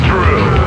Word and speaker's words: Drew: My name is Drew. Pronounced with the O.